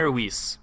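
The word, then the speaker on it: Mainer Weisse: Mainer Weiss. – Mainer Weiss. – So two words, Mainer, like you're from the state of Maine, and then Weiss, like the kind of beer.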